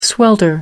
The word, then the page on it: swelter: American English